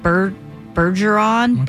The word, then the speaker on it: Bergeron: I can never say his last name right. Tom Bergeron? – You said it right.